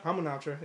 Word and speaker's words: Hamunaptra: Hamunaptra? – Hamunaptra, yeah.